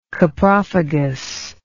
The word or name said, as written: coprophagy